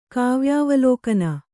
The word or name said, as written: ಕಾವ್ಯಾವಲೋಕನ